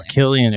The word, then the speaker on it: Cillian: Or Killian Murphy? I never know how to say his name. – Yeah, we found out it was Killian.